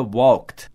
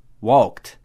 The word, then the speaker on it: walked: Porque, por exemplo, quando o verbo termina com k, o ed vai ter um som de t. Então, walk no passado fica walked, walked.